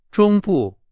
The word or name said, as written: 中部